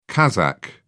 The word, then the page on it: kazakh: British English